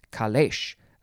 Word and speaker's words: calèche: It is spelled C-A-L-E-C-H-E, and there's an accent over the first E, calèche. So yeah, it looks like they just changed the spelling.